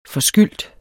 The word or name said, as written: forskyldt